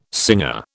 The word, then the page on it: singer: British English